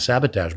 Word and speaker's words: sabotage: And sure enough, he said, he's trying to sabotage my ship. My crew. Please don't sabotage it. So that is actually the French-Canadian pronunciation of it. Yeah. Sabotage.